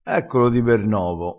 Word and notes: di ber novo: dialettale

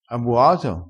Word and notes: bua: dialettale

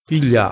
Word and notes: piglia: dialettale

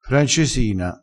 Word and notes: francesina: dialettale

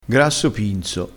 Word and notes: pinzo: dialettale

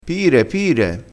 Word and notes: pire pire: dialettale

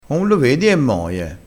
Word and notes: moie: dialettale

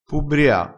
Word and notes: pubbria: dialettale

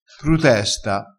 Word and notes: prutesta: dialettale

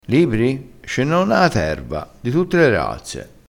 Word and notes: di tutte le razze: dialettale

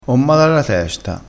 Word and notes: mar: dialettale

